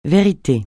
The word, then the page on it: vérité: francais